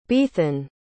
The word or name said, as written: baethan